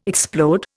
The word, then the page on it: explode: Indian